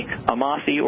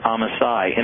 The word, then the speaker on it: Amasai: It is the one and only Amasai, or as you pronounced it, Amasai.